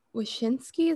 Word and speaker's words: Wyshynski: But Greg Wyshynski, is that how you say his name? – Yes.